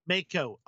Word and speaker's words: Mako: And I have always like, is it Miko? Is that how you pronounce her name? – Mako.